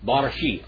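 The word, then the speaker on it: Barashit (בראשית): How do you say Genesis in Hebrew? Barashit.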